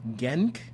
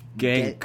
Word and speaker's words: Genk: Meanwhile, Genk, I don't know. – How do you spell it? – G-E-N-K. – Genk.